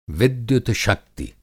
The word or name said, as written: विद्युत-शक्ति